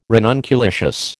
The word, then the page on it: ranunculaceous: American English